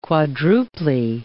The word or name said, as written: quadruply